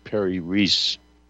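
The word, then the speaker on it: Piri Reis: Pyrrhus Rhee, I believe, was that his name? – The way they pronounce it today is Perry Reese.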